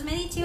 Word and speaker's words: Medici: Los Medici o la familia de los Medici, este, van acento en la E era una de las familias más importantes de Florencia y tenían muchísimo dinero.